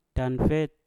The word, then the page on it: kanfet: Mpur